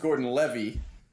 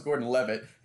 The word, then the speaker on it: Gordon-Levitt: has adamantly argued that his name is Joseph Gordon-Levy, and you don't pronounce the T's, and... Tyler and I have just been arguing with him until we're blue in the face that it's Joseph Gordon-Levitt, and he just let slip how to pronounce it correctly.